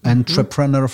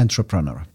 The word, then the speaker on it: entrepreneur: No, ale bardzo ładna brytyjska wymowa tego słowa. Jeszcze raz. – Entrepreneur. – Pięknie. I amerykańska będzie... – Entrepreneur. – Z początku będzie troszeczkę inna, bo to będzie nieco bardziej przez A.